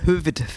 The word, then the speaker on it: Hövede: Und ist es Hövede oder Höfede? – Hövede. Hövede. Also mit V schon geschrieben aber mal, anders ausgesprochen.